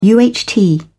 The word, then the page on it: UHT: British English